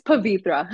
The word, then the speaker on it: Pavithraa: It's Pavithra.